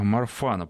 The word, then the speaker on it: Марфана: А, у меня синдром э, Марфана, правильно я ударение ставлю?